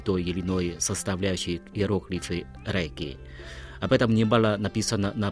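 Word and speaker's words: Рейки (霊気): Но против всего напоминаю, что эти два иероглифа «Рейки» произносятся как «Рейки» только по-японски, а в китайском языке их произношение «Линчи», но они имеют одинаковый смысл.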